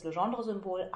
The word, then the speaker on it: Legendre: Dann gibt es das Legendre-Symbol a über p. So wird das ausgesprochen und es wird geschrieben, als ob ich den Bruch a durch p hinschreiben wollte und dann noch runde Klammern drum setze.